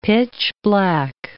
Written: pitch-black

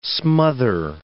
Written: smother

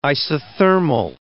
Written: isothermal

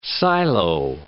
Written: silo

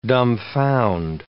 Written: dumbfound

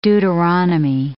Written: Deuteronomy

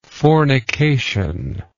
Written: fornicatory

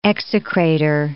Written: execrator

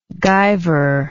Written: guiver

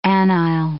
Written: anile

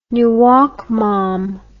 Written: nuoc mam

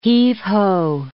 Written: heave-ho

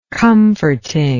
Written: comforting

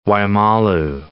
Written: Waimalu

